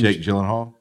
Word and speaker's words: Jake Gyllenhaal: Jake Gyllenhaal? – Yeah.